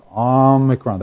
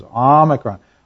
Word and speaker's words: Omicron: It's a short O sound, Omicron. That's where the emphasis is, Omicron.